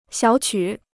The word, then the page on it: 小曲: xiǎo qǔ